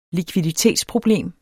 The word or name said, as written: likviditetsproblem